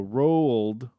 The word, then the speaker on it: Roald Dahl: All I typed in was how to P-R-O-N, and it autofilled pronounce Roald Dahl. So it's Ro, and then it says U-H-L-D. So Roald Dahl. D-A-A-L. – So it's kind of like when people try to say the word rural. – Rural. – Rural. Yeah. – Rural. Okay, Roald. It's so two syllables. Roald.